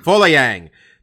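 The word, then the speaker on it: Folayang: How do you say the kid's last name? – Foleyang. – Foleyang.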